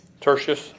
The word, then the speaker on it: Tertius: And Tertius, is that how you say it? – Tertius, okay.